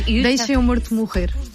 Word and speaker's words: Deixa-me Morto Morrer: Am I pronouncing that? Am I butchering that pronunciation? – It's in Portuguese, it's very hard to pronounce Portuguese, but it's almost. – No, you Deixa-me Morto Morrer. We eat the vowels, so it's very hard.